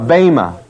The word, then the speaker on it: Bema: B-E-M-A, B long E-M-A. The Bema.